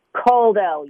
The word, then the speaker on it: Kuldell: Kuldell, you got it.